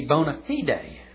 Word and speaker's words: bona fide: Actually, that's much mispronounced. It should be bona fide, meaning in good faith.